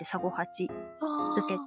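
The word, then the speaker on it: 三五八漬け: なんかサゴハチは、うん、漢字で数字の 358 って書いてサゴハチ漬けって読むんですけど、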